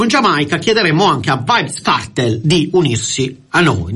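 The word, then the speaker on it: Vybz: come si pronuncia Vibes, io avrei detto Vibes, diceva Vibes, allora abbiamo detto Vibes.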